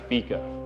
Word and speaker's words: fika: It's pronounced fika.